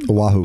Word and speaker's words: Oahu: He lives in Oahu. Am I saying that right? – Oahu.